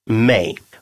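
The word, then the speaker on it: mig: «Мэй» – «меня», «мэй». Мы сталкиваемся с разговорным вариантом написания этого местоимения. Более правильное состоит из других трех букв «м» и «г», а читается также «мэй».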